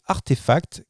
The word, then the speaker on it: RTFKT: société française Artefact qui s'écrit RTFKT. Et Artefact, ils font quoi?